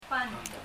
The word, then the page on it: band: bandɔ